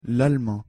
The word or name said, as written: l'allemand